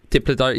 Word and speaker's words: Diplodocus: Diplodocus.